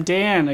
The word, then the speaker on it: Dan: I'm Dan again.